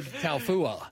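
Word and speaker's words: Taufua: I love it when you pronounce the Polynesian names. – That was right. – Taofua.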